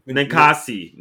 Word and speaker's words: Ninkasi: You said Ninkasa, right? Ninkasa? Yeah, Ninkasi? – Ninkasi. Ninkasi Brewing out of Eugene.